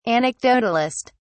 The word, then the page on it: anecdotalist: British English